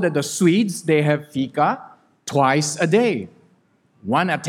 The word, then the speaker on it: fika: Now, how do you pronounce this word? Easy, Fika. Fika.